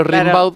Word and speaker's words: Rimbaud: Claro, se pronuncia Gimbaud, pero Rimbaud.